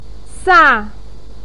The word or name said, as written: sa4